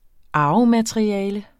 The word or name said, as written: arvemateriale